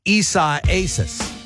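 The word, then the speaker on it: Isaías: I found out how to say it. It's from Spanish, and it means Isaiah. Isiasis.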